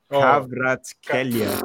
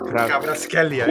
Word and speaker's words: Kvaratskhelia: Kvaratskhelia. – Kvaratskhelia, yeah.